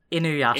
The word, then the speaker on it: Inuyasha: Inuyasha. – Could you say that one more time? – Am I saying this wrong? – Inuyasha.